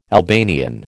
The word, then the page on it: Albanian: American English